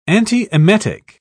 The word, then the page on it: antiemetic: British English